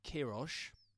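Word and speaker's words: Queiroz: Carlos Quiroz has managed nice pronunciation.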